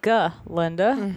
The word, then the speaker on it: Galinda: And in this conversation is where she corrects him and says, it's Galinda, the proper old Gilliganese pronunciation, if you don't mind.